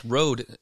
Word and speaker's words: Rode: Am I pronouncing it correctly? Rode? – Yep, correct. Rode. The Rode.